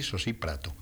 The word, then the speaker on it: Sosíprato: Lleva un acento en la I, Sosíprato.